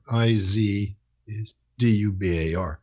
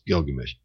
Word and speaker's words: Gilgamesh: And uh, I learned that Izdubar was the old way in Jung's time that uh, Middle Eastern scholars thought the pictograms would be pronounced. – Mm-hmm. – And they've changed their mind. And so it's the same God. It's just they realized that they were wrong in the pronunciation of the pictograms. And so now the word is pronounced Gilgamesh.